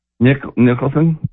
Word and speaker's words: Nicholson: Nicholson? Is that how you say his name? – Yeah.